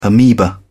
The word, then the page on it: ameba: British English